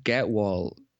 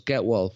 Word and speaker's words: Gehwol: Now, she recommended a cream called Getwall cream. Now, I'll spell that Gehwol, foot cream. So it's Getwall foot cream.